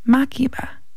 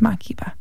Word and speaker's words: Makeba: And then we heard Pata Pata from Miriam Makeba. I said that wrong last time, I think. Makeba. It's Makeba.